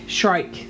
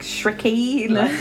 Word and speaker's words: Shrike: Shrike. – Okay. – See, now, this... – Shrike, Shricky.